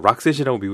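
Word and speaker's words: Roxette: 예, 스웨덴 출신의 혼성 듀오 록셋이 불렀고요. 락셋이라고 미국에서는 발음을 하죠.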